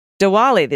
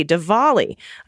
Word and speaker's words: Diwali: Diwali. The D is pronounced like a V. Diwali.